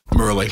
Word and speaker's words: Murali: I saw Mularatha, I'm not saying it right, getting called Sri Lankan spinner. – Murali. Daryl Hare, the umpire.